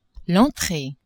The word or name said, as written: entree